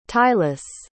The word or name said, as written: tieless